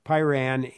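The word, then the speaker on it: Pyran: He was a co-founder of Pyrin, P-Y-R-A-N. I keep wanting to kind of mispronounce that. Sorry. Pyrin in 2018.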